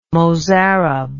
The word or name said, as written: Mozarab